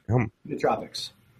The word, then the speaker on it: nootropics: Am I saying it right when I say neurotropics or is it nootropics? – Nootropics, man.